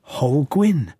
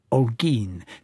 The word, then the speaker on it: Holguín: Depending on the mood and linguistic capabilities of the airport announcer, it might be Holguín or Holguín, or very occasionally the correct rendition, Holguín, named after a conquistador, García Holguín.